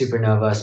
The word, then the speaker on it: SuperKnova: It's SuperKnova, spelled with a silent K in the middle, SuperKnova, SuperKnova Music, same for all the social medias at SuperKnova Music.